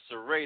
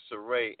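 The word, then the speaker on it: Zairé: It's Zaire the Maestro. It's a hyphen over the E, which will give it the pronunciation of an A sound. – Okay. – Yeah, so that's Zaire.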